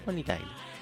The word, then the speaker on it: Bonnie Tyler: Vamos con Bonnie Taylor. O Bonnie Tyler, ¿cómo se dice? Bonnie Taylor. Bonnie Tyler. Sí. Bonnie Tyler.